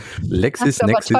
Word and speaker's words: LexisNexis: Genau, die Kolleginnen und Kollegen von Heiser haben jetzt aufgeschrieben, dass LexisNexis, ist von wunderschöne, LexisNexis.